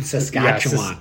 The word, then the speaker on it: Saskatchewan: But then we met these guys from Saskatoon, Saskatchewan. – Oh, yeah. Say it right. Saskatoon, Saskatchewan. Yeah, Saskatchewan, Saskatchewan.